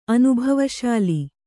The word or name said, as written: ಅನುಭವಶೀಲ